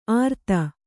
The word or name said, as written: ಆರ್ತ